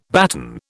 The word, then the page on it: baton: British English